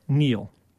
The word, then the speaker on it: kneel: K-N-E-E-L, Neil.